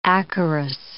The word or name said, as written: acarus